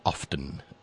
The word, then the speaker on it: often: I was taught never to pronounce the T in often. But if you look it up, you'll find that apparently it is acceptable to say often as well.